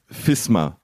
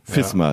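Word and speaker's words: Visma: Und dann haben sie ein Video veröffentlicht, wo Wout van Aert deutlich in die sagt, unsere Mannschaft heißt FISMA, also mit F vorn, FISMA, ja.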